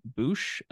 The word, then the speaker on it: Boush: You can find me on not Instagram, Twitter and TikTok at Jacob Boosh, Boush, like the bounty hunter. I recently found out that I have been pronouncing that wrong my entire life, but it's fine.